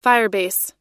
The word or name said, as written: Firebase